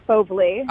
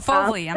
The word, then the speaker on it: Fauvely: So it's actually Fauvely. – Oh, Fauvely. I'm sorry. Fauvely. – Yeah. No, it's okay. It gets butchered all the time. Fauvely. It's French for wild beast.